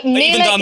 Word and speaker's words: Mela: Did I pronounce that correctly? Mela? – Correct, that's right, yes.